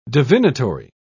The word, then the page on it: divinatory: British English